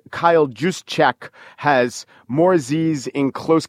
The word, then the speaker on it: Juszczyk: The Harvard guys in question are Matt Burke, who is the center, and his fullback who went to school is Kyle Juszczyk. I think it's Juszczyk. He has an interesting, almost impossible to pronounce name, J-U-S-Z-C-Z-Y-K.